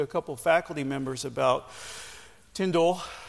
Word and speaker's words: Tyndale: So let's talk about William Tyndale today. Yes, that's how you pronounce his name. Tyndale.